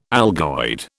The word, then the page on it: algoid: British English